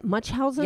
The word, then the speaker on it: Munchausen: And it, she has, what is it? Munchausen? – Yep. Yep. – Is that how you say it? – Yeah. Yeah. It's close as what I thought.